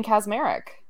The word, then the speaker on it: Kaczmarek: Yeah, it's Jane Kaczmarek.